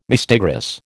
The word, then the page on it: mistigris: American English